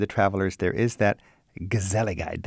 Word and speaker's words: gezelligheid: The word is gezelligheid, and it's as hard to pronounce uh, as it is easy to understand once you've experienced it.